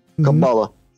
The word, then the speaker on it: Каббала: И все, что я могу добавить к э, сказанному тогда, ранее, я могу сказать, что Каббала, или иногда в оккультно исторической практике делается ударение на другом слоге – Каббала.